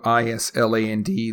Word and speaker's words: island: silent letters, or the letters are not actually pronounced in the word, such as the word island. It's spelled I S L A N D. Looks like it may be pronounced is, Island or Island, but it's island.